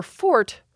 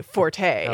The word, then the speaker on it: forte: And I said to John, well, one solution is to say that's her fort, or as some people would say, forte. – Oh, that's smooth. Yeah. – Right? So you get to cover your bases there about how to say the word. – But forte sounds better, doesn't it? – Well, it does. It's influenced by the Italian. The French would say fort, which becomes fort in English, but now very often we say forte.